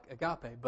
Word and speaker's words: agape: There's an English word that's spelled A-G-A-P-E, just like agape, but it's agape.